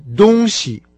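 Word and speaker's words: «дунси» (东西): Поэтому просто запомните, что вещь по-китайски «дунси», а от словосочетания «восток» и «запад» это слово отличается тем, что начальный его компонент произносится со своим обычным первым тоном, а второй – с легким.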